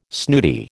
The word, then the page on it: snooty: American English